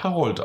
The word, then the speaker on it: Harold: Harold? Nein, Harold. Der wird Harold ausgesprochen. Es ist ein deutscher Entwickler dabei und der besteht darauf, dass das Harold heißt.